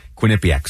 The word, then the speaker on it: Quinnipiac: making sure we could correctly pronounce Quinnipiac.